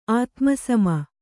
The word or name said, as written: ಆತ್ಮಸಮ